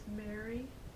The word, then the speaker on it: Mary: Yes, my name is Mary, that's M-A-R-Y, Lee, L-double-E, Younger, Y-O-U-N-G-E-R.